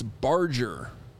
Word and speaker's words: Barger's: The beef place I gave out, which apparently people loved, and it sounds like it's been a bit, but I pronounced it wrong. It's barger. – Bargers.